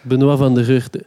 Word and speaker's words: Benoit van de Geuchte: Sorry, moet ik het even goed uitspreken? Sorry, a.k.a. Bunny Vlogs. – Benoit van de Geuchte. – Van de Geuchte, sorry.